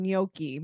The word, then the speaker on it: gnocchi: Is it pronounced gnocchi? Because I've been saying gnocchi my whole life.